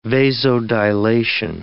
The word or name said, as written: vasodilation